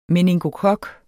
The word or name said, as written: meningokok